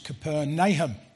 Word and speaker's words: Capernaum: You probably heard of it in the Gospels called Capernaum. Well, it's actually pronounced Capernaum.